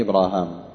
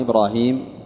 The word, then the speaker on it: إبراهام: قال: قلت، ذهبي يقول: رسم المصحف محتمل للقراءتين، لأنها مرسومة ألف باء راء هاء ميم، هاء ميم، فمن قرأ إبراهام فهناك ألف تنطق ولا تكتب بعد بين الهاء والميم، ومن قرأ إبراهيم فهناك ياء تنطق ولم تكتب في المصحف إبراهيم.